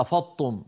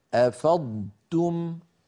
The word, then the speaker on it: أفضتم: ولا نقول مثلا افضتم في بعض اخواننا بيحولون الصوت كله إلى طاء مشددة فيقول أفضتم إيش هذه أفضتم لا يصح يا أخي أفاض يفيض أفضتم فعلينا أن ننطق ضادا صحيحة ساكنة ونتبعها بتاء مضمومة مرققة هكذا أفضتم أنبه